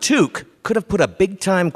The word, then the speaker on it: toque: Toque.